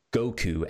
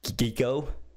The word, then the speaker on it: Goku: Goku was they mispronounced Goku as Geeko.